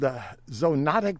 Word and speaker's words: zoonotic: This will heighten the risk of what is called zoonotic spillover.